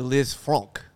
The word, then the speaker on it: Lisfranc: Napoleon's army, it's definitely Liz Frank. I'm telling you, it's Liz Frank.